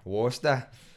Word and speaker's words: Worcester: Is that how you say it? Mm-hmm. Worcester? – Worcester.